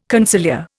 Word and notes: conciliar: Indian

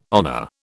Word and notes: honour: British English

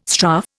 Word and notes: strafe: Indian

